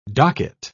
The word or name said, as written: docket